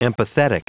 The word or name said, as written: empathetic